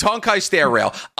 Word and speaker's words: Honkai Star Rail: And so I got an email today from Honkai Star Rail. Is it? – Say the name right. – It's Honkai Star Rail.